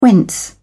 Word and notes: whence: British English

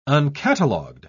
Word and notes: uncatalogued: British English

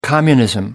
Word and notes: communism: American English

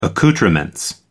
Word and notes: accoutrements: American English

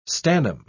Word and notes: stannum: British English

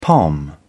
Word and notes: pom: British English

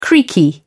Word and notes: creaky: British English

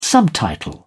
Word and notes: subtitle: British English

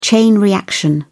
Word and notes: chain reaction: British English

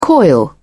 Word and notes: coil: British English

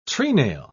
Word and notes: trenail: British English

